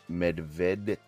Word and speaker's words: Medvedenko: Uh, no, it's M E D V E D E N K O Medvedenko.